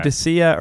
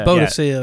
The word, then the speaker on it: Boudicca: Bodica? – Bo- yeah, it depends on how, how you, you pronounce, pronounce, it. it. Yeah. Boudicca or Boudicca. Boudicca, yeah. – Boudicca I would say.